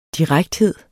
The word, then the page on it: direkthed: ˈdiʁagdˌheðˀ; diˈʁagdˌheðˀ